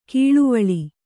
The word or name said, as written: ಕಳವಳ